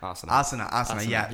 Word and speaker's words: Asana: And more like you were saying, is it NASA? How do you say it? The poses. Well, what is it? – Asana. – Asana. Yeah. You're correct in that.